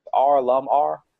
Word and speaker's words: R.LUM.R: It's R Lum R.